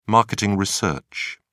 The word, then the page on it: marketing research: British English